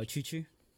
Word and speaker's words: Chuchu: Oh, Chuchu.